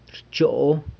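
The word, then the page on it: rchoo: San Lucas Quiaviní Zapotec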